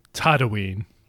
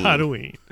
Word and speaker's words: Tatooine: Once upon a time, on a distant planet called Tatooine. I got to say it wrong. – Tatooine.